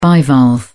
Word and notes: bivalve: British English

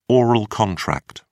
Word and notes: oral contract: British English

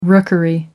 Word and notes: rookery: American English

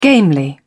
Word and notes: gamely: British English

